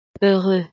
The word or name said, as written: heureux